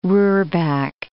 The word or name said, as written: roorback